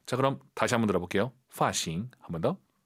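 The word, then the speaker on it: 发型: 어, 꽤 많은 분들께서 파싱 이렇게 발음해요, 선생님. F 발음을 좀 어려워하세요. 파싱, 파싱 아니고 파싱. 도파 아니고 더파. 정확히 F 발음 소리 많이 나야 됩니다.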